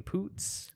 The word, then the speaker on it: Poots: Okay, look, I've got, and I just want everybody to listen to this too, because I looked up how to pronounce it, and this guy, I feel like, is like saying it, he says it three times, and it's almost like, yes, that is her name. Her name is Poots.